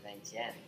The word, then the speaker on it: Vientiane: Vientiane? Yeah, Vientiane.